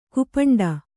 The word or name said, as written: ಕುಪಂಡ